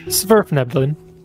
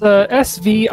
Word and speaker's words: Svirfneblin: Svirfneblin. – Svirfneblin. – One more time. – It's Svirfneblin.